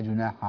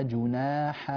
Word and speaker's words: جناح: في قوله تبارك وتعالى: فلا جناح عليكم، وهذه الكلمة تكررت عدة مرات في هذا المقطع، فالحاء مع العين هنا لابد من أن لا يكون في نطقها التباس واشتباه في الصوت، فلا بعض الناس مثلا عندما يقرأ يقول فلا جناح عليكم، لا جناح عليكم، فلا تظهر معه الحاء، وبعضهم يدغمها في بعض، هذا غير صحيح، لنبغي أن يكون النطق واضحا تماما.